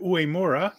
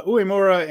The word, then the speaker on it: Uemura: Here's the special singles match: Yota Tsuji and uh, Yuya Uemura. And I said it right the first time. Right, look at me. Uh, Uemura and uh, Tsuji.